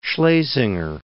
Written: Schlesinger

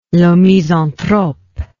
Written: le misanthrope

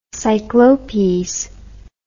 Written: cyclopes

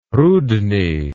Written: rudny

